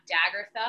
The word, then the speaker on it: Daggertha: But you can find me at Daggertha on Instagram. If you say it with an Australian accent, like my family, it rhymes with Agatha.